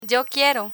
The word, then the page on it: yo quiero: spanish